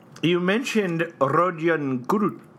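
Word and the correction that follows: Kuruc: Yeah. – Kurutz. Yeah. Ian Eagle helped clarify how to pronounce that.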